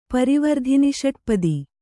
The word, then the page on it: ಪರಿವರ್ಧಿನಿಷಟ್ಪದಿ: parivardhini ṣaṭpadi